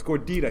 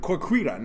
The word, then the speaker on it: gordita: I'm like, dad, you're saying it wrong. It's Gordita. He's like, Corquita. No, I'm like, Gordita.